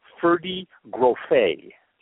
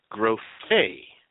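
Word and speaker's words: Ferde Grofé: That's Ferdi Groffé. – Groffé.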